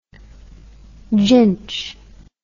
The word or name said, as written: ginchy